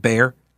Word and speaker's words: Bexar: Bexar County, that's Bexar County, but I know it's pronounced Bear.